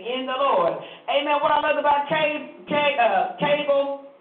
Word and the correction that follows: Caleb: Am I saying his name right? Cable. Caleb.